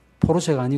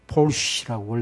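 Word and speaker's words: Porsche: 뭐 포르쉐 타고 다니는 포르쉐가 아니고 뭐 폴쉬라고 원래 발음이 그런데 어쨌든 간에 뭐 그거 그렇게 부럽지 않습니다.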